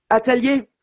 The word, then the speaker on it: atelier: Am I saying Atelier correctly? – You're perfect. Atelier. Yeah, you got it.